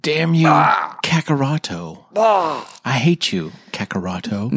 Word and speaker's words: Kakarot: Kakaroto. – Until you tell me to stop. – That's correct. Oh, that is the correct way to say it.